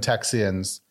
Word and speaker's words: Texian: I see it written as Texian. Is that the more proper pronunciation? – Yeah, it's one that Texans today are not familiar with at all. But back then, it would have been Texians.